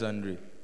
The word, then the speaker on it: Andre: I'm Andrew. – Please, oh, say your name like it's yours. Why? Yes, Isaac. – My name is Andre. – Is? – Andre. – Andre, yes. Go ahead.